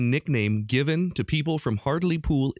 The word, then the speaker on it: Hartlepool: Hartlepool, Hartlepool. Spelled in America, we would pronounce it Hartlepool. H-A-R-T-L-E-P-O-O-L.